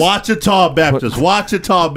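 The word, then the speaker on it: Ouachita: At Ochita Baptist? – Wachita Baptist.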